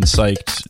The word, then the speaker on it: psyched: It's interesting to me how if you switched the silent P in psyched with the second letter, which is, of course, an S, it's spiked.